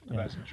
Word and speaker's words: Vezina: Is it pronounced that way? – Yeah, Vezina Trophy.